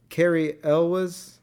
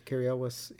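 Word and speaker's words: Cary Elwes: Carrie Elwes. – Yes. – Is that how you say his name? – Yes. – Carrie Elwes.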